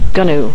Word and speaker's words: GNU: Actually, it's pronounced GNU, folks. – GNU, yes. So there you go, GNU.